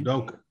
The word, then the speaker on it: Udoka: Ime Udoka, I believe is his name. – Udoka.